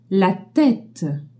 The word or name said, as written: tête